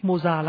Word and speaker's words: Mozart: Tiếng Đức tên Mozart đọc là Mozart, nhưng mà người Việt Nam mình đọc theo tiếng Pháp đã quen là Mozart, mà nghe nó nhẹ tai hơn, thôi mình đọc là Mozart đi nhá.